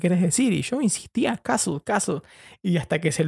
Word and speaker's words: castle: Se escribe castle, pero se pronuncia castle, no se pronuncian la, la T ni la E final.